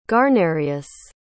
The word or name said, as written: guarnerius